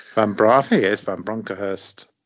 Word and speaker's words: Bronckhorst: Van Bronckhorst. – Van Bronckhorst.